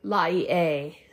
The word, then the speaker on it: Laie: and then the Laie what it's called. It's, it's spelled L A I E.